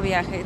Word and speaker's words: Viaje: Periódico Viaje.